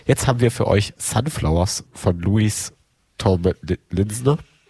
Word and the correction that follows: Tomlinson: Linsorn? Wie wird es ausgesprochen? Kann mir jemand helfen? – Sunflowers von Louis Tomlinson.